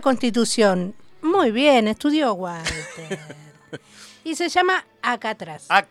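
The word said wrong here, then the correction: Ácratas: Acratas. – Ahí está. – Acratas. Ahí está. Perdón, lo dije bien, Acratas.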